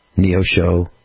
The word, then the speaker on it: Neosho: And they've got some shows this weekend, February 8th and 9th, Neo Show, Missouri. N-E-O S-H-O, pronounced Neosho, Missouri.